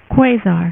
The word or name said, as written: quasar